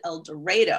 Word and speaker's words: El Dorado: The first thing your listeners need to know is that even though it sounds strange, in El Dorado, they actually pronounce it El Dorado.